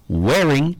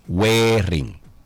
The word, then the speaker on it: Whering: Se llama wearing, pero se escribe W-H-E-R-I-N-G. wearing Yo tengo otra que se llama A Closet.